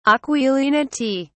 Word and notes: aquilinity: British English

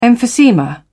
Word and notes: emphysema: British English

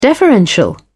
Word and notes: deferential: British English

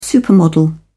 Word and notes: supermodel: British English

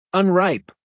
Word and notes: unripe: American English

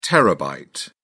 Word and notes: terabyte: British English

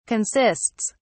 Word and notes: consists: British English

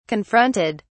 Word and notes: confronted: British English

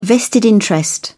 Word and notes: vested interest: British English

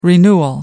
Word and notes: renewal: American English

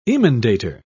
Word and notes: emendator: British English

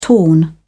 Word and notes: torn: British English

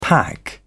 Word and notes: pack: British English

